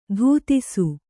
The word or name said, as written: ಧೂತಿಸು